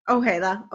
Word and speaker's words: Ojeda: Is that how you pronounce it? – Ojeda. – Okay, of course, it's in Spanish.